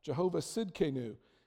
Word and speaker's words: Jehovah Tsidkenu: It's Jehovah Tsidkenu. The T is silent. Jehovah Tsidkenu.